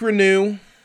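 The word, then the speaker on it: Reneau: Malik Renew, I have that pronunciation right. We got it set.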